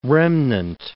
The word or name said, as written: remnant